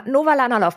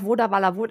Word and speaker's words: Novalanalove: Novalanalov. Ha, richtig ausgesprochen. Ich äh, glaube, ich bis vor kurzem immer noch Vodavala.